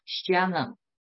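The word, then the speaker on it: Shalom (שלם): 撒冷它发音呢就叫 Shalom。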